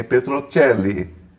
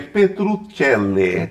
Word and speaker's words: Petrocelli: a gente fica brincando com, esses, com essas pronúncias, é como o meu sobrenome de família, que é Petrocelli, né? que descobrimos recentemente que é Petrucelli.